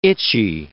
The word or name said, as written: itchy